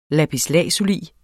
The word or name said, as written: lapis